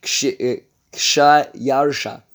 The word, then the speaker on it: Xerxes: And this is the Xerxes is, in fact, the way it is pronounced is Kshayarsha, which is in Greek, Xerxes.